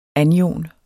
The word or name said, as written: anion